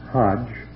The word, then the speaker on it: Hajj: So the pilgrim, Haji, H-double-J, which may always be pronounced Hajj, H-double-J, to Mecca, from which, of course, the Hajira, the flight, derives its name.